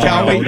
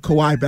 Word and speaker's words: Kawhi: Before Larry Johnson would mispronounce Kawhi Leonard's first name, call him Cowie? – That's a cheap shot. – I wasn't shot. – Listening back then, I must admit. – Cowie Leonard!